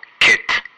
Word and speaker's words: kit: En Estados Unidos y en inglés británico estándar, tiende a sonar como una I relajada. E. Kit. Kit.